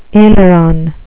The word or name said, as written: aileron